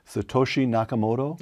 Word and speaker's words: Satoshi Nakamoto: In 2008, this anonymous person who calls himself, I'm not sure I can pronounce this, Brian, so correct me, Satoshi Nakamoto? – That's right.